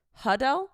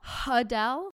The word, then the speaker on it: Adele: We've all been pronouncing Adele's name wrong. – It's Hudel. – Hudel.